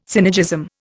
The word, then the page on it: synergism: Indian